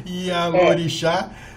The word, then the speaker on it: Ialorixá: Hoje nós vamos falar de candomblé e para falar sobre candomblé, estou trazendo para vocês a Lorixá. o nome é complicado, né? Ialorixá, é.